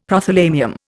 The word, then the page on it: Prothalamium: Indian